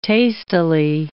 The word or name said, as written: tastily